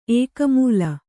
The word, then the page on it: ಏಕಮೂಲ: kannada